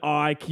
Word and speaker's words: Icke: "It's pronounced David Icke, you fucking idiots."